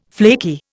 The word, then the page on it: flaky: Indian